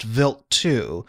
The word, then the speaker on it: Wild 2: It was Stardust flying past Vilt 2, Vilt spelled WILD.